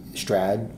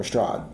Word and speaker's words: Strahd: The two main ones be like uh, be Strahd or Strahd, right? Yeah and both are fine.